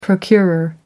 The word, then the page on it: procurer: American English